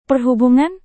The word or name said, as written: perhubungan